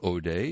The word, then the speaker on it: Odeh: Iman, first name is spelled A-Y-M-A-N. Last name, O'Day, is spelled O-D-E-H.